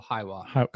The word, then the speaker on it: Hiwa: How do you pronounce that? Hiwa? H-I-W-A? Coconut. – I would go Hiwa.